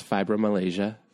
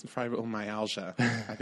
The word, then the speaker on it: fibromyalgia: She has fibromyalgia. Is that how you say it? – No, it's fibromyalgia. This is the second time I've corrected you on this.